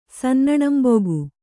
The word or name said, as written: ಸನ್ನಣಂಬೊಗು